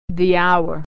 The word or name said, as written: hour